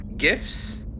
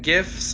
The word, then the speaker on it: GIF: What's crazy is like I'm Googling it and I'm seeing a lot of images, but I'm not seeing any GIFs. – Oh. – How do you all pronounce that word? Do you pronounce it GIFs, like a gift for Christmas, or GIF, like the peanut butter? – GIF.